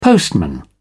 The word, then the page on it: postman: British English